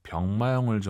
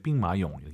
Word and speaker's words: 兵马俑: 나 시안 가서 병마용을 좀 보고 싶네. – 我想去西安看看兵马俑. – 兵马俑. 이런 고유명사도 알아두시면 좋아요. 병마용, 병마용 하지 마시고 이제 빙마용 예쁘게 발음하시기 바랍니다.